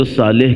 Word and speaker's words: صالح: اردو میں چونکہ سات کا تلفظ بہت اچھا نہیں کرتے تو صالح کہہ دیتے ہیں، لیکن صالح عربی میں کہیں گے۔ صالح کون ہے؟